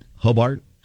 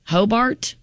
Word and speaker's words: Hobart: But the is it Hobart? Is that how you say it? – Hobart. – Hobart. H-O-B-A-R-T.